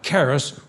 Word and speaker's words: charis: And it's spelled C-H-A-R-I-S, like charis, where we get a word for charismatics, which means spiritual gifts. But that's really a mispronunciation. It's charis.